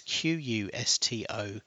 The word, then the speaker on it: Qustodio: So the one I'm using is something called a Qustodio, and that's spelt with a Q, so that's Qustodio.